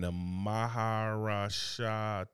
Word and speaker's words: Maharashtra: in India State and Maharashtra, okay.